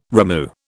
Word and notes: rimu: British English

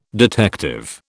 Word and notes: detective: British English

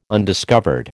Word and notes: undiscovered: American English

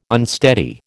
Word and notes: unsteady: American English

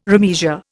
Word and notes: remeasure: Indian

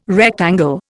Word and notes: rectangle: Indian

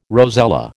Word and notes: rosella: American English

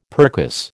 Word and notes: percuss: American English

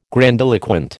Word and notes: grandiloquent: American English

